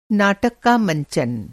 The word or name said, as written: मंचन